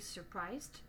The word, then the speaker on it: surprise: On dit donc il est surpris, he is surprised, mais elle est surprise, She is surprised. On prononce surpris et surprise.